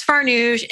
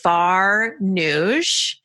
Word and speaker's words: Farnoosh: And I'm like, my name is Farnoosh. It's very easy. It sounds just like it's spelled Farnoosh.